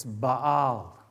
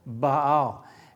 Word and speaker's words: Baal: And the correct pronunciation, those of you that were wondering, it's Baal. There's actually an apostrophe in between the B-A and the A-L. Baal.